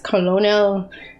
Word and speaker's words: colonel: I seriously hate that the word spelled colonel is pronounced colonel.